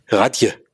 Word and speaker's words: Radj: Das war, ich kann die, deswegen habe ich den Titel bisher umgangen, weil wie spricht man R-A-D-J aus? Radje. Radje.